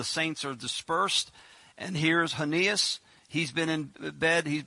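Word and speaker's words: Aeneas: And there he found a certain man named, this is going to be kind of hard to pronounce, but I'll give it to you, Hanias. It doesn't look like it, but that's how you pronounce it, Hanias.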